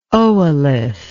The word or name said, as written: oolith